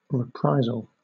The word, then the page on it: reprisal: British English